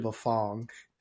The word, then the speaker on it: Bafang: And this is another one, by the way, I've been pronouncing wrong for years and I can't change. It's actually Bafang, and I get it right like 1 out of 10 times.